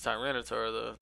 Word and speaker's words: Tyranitar: His full, yeah, I think it's Tyranitar though.